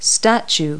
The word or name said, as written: statue